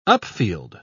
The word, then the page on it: upfield: British English